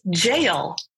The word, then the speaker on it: gaol: But it is actually pronounced as jail, which is something that my dumb American ass would not be able to know looking at it when you spell it G-A-O-L.